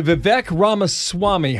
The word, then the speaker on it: Vivek Ramaswamy: Big welcome to Vivek Ramaswamy.